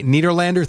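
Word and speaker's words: Nederlander: It's called Take a Banana for the Ride at the Niederlander Theater.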